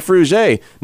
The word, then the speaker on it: Frugé: But they're like, hey, you know that you say her name Frugé?